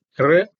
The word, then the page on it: r: Sanskrit